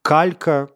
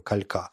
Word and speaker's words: калька: Например, калька, которая на французском кальк, ну то есть там ударение вполне себе понятно, а мы не называем же ее калька или э,